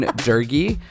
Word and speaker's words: Durgy: Mine is Ryan Durgy, R-Y-A-N. – It's your name. – D-U-R-G-Y, because I am original and creative like that.